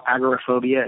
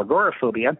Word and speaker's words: agoraphobia: So the word we're talking about is the fear of the marketplace, the fear of, being out in open spaces, which some people will call agoraphobia and others will call agoraphobia. Agoraphobia is the older, more traditional pronunciation, and agoraphobia is relatively newer as far as English pronunciations go.